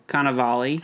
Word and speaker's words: Cannavale: How do you say his name? Cannavale.